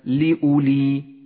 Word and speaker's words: لأولي: بعد ذلك اقرأ كلمة لأولي لأولي، ولاحظ هذه الواو الرمادية وأسقطها من اللفظ، لذلك لونها لك مصحف التجويد بهذا اللون لأنها لا تلفظ لأولي.